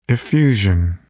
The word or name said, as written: effusion